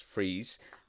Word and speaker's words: Fries: Uh, so Doctor Victor Freeze, like you say, it's spelt as you would probably say fries, but it's pronounced Freeze.